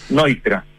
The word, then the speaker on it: Neutra: Leía que era un gran admirador de Richard Neutra, no sé si está bien pronunciado Neutra, pero... – Neutra.